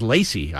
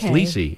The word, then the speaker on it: Lise: I thought all these years her first name was Lacey. I didn't know. L-E-C-Y, but okay. – It's Lise. Yeah.